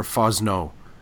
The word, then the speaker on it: Fosnot: I saw one of your blog posts mention something, I don't know if you're attributing it to, is it Kathy, is it pronounced Fosnot or Fosno? – I pronounce it Fosno, but I know if people have pronounced it Fosnon, but when we'll see if we get any notes back on this, you know.